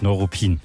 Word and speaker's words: Neuruppin: Ja, die amtlich offizielle Aussprache ist natürlich Neuruppin. Ähnlich wie Neukölln oder auch Neubrandenburg. Also kurzes Neu.